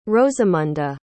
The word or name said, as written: rosamunda